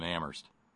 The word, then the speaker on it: Amherst: Kind of like only the H is silent in Amherst.